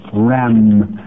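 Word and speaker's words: ram (ראם): Unicorn is, in Hebrew, is spelled R-E-M, but it is pronounced in Hebrew ram, and it means a wild ox.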